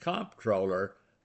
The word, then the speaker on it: Comptroller: Finally, I'm given the chance to expound on the correct pronunciation of Comptroller, Comptroller. It's exactly the same pronunciation as the word controller, Controller.